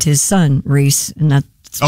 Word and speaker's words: Rhys: Does he pronounces it Reese? – Reese? – Does he? I don't know. – Oh, it is Reese.